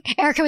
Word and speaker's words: Erika: Erika with a K.